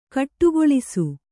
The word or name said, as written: ಗೂಡಗೊಳಿಸು